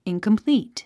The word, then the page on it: incomplete: ìnkəmplíːt; インコンプリート